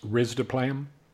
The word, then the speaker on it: risdiplam: Another drug, which is called, I'm probably going to butcher the pronunciation here, but I'm going to say risdiplam. Is that, I don't know. – It's actually pronounced risdiplam.